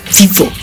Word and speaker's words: vivo: Así, su pronunciación es muy vivo.